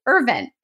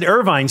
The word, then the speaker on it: Irvine: I'm from Northern Ireland and I grew up in my name there is Irvin. And when I came to the US, a lot of people pronounce it Irvine. So I really go by both. But when I talk about home and growing up and when I go home, everyone still calls me Irvin.